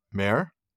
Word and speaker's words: mearh: Well, Marco is derived from Old English, and I'm going to pronounce this incorrectly, mare? – I think that's right. – M-E-A-R-H? Mm-hmm.